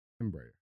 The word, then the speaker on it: Embraer: Embraer. – Got it. The Embraer.